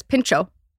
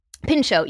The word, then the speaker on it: Pintxo: Pincho. – Pincho. P-I-N-T-X-O. Mm-hmm. That T and the X make that ch ch.